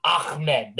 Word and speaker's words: Ahmed: He gave you a play about Salvon Ahmed, whatever, however you say his name. This guy has been ridiculous. – Gotta put the emphasis on the Ahmed. There we go.